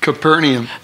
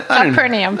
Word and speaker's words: Capernaum: So when the crowd saw that neither Jesus nor the disciples were there, they got into the boats and went across to Capernaum. – Capernaum. – That's the word. I knew I was saying it wrong. – Well, Capernaum. – That's the way we're going to say it.